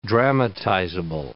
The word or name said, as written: dramatizable